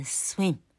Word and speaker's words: swim: 好，所以 swimming，好，要念 swim，所以叫 swimming，swimming。